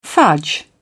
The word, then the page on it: fudge: British English